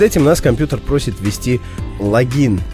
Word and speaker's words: логин: А надо ввести логин, только такое ударение.